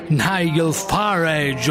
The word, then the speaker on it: Nigel Farage: Nigel Farage, o come diavolo si pronuncia lo stesso, avete capito benissimo.